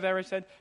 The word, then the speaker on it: Erised: Did I say that right? The Mirror of Erised? Yeah, I'm getting a couple nods. Good. Okay, said it right.